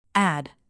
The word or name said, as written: add